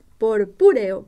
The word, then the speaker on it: purpúreo: A menudo el tallo y hasta las venas de las hojas toman intenso color purpureo. El fruto A ver, vamos a pronunciarlo bien. Purpúreo, purpúreo, a ver.